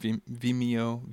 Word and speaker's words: Vimeo: Again, if you haven't seen Daniel's work, find Daniel Thomas on Vimeo. I think that's the way you pronounce that site. That's what I've always called it. Vimeo, V-I-M-E-O.